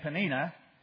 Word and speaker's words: Peninnah: But we're pronouncing her name Peninnah, if we're consistent, and his name Elkanah.